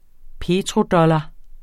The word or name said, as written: petrodollar